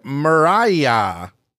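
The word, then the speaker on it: Murraya: She crushed the competition with the winning word Mariah. Mariah.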